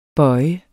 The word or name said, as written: bøje